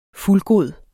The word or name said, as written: fuldgod